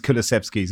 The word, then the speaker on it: Kulusevski: Kane and him have gelled really well with Kulusevski, and I haven't said that right, have I? – We'll just edit in one of Johnny's Kulusevskis, and no one will notice.